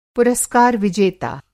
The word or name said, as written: पुरस्कार विजेता